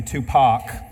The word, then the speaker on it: Tupac: I think I just said Tupac. It's actually Tupac. My bad. I was rhyming with Janet there. My bad. I was just filling the rap in me, so it just came out Tupac. Sorry, it's Tupac.